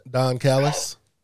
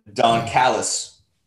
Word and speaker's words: Callis: You could have Kenny Omega roll up with the Good Brothers and the Hardys, and Don, say his last name. – Don Callis. – I've been saying it wrong. Don Callis, and you know you have Don Callis.